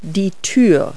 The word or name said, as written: doorway